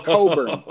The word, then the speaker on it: Cockburn: Well, if you need a little bit more emphasis, C-O-C-K-B-U-R-N. So it's pronounced Coburn, but it's spelled something totally different.